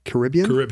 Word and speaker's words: Caribbean: Caribbean. Okay. He's from the, you really put the rib back in Caribbean.